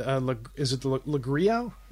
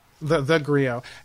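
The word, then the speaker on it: theGrio: I think it was Coronavirus News for Black Folks and Le Grio? Is that how you say it? – Oh, Le Grio. – The Grio.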